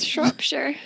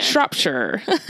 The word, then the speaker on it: Shropshire: I don't know if I'm saying that right, but it's Shropshire. Yeah. – Shropshire.